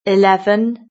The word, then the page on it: eleven: British English